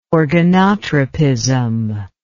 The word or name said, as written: organotropism